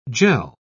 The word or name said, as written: jell